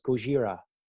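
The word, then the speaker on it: Gojira (ゴジラ): Oh, I learned how to pronounce it also in Japanese. My friend speaks Japanese. She's like, no, it's Gojira.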